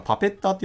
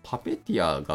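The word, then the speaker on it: Puppeteer: で、あとはまあ E2A として、えー、サイプレスとかプレイライトとかパペッターパペティアというものでしたっけこれ確かパペッターって読み方じゃないんですよね。パペティアが確か正しい読み方だった気がしますけど、まあ、その辺を使うとかですね。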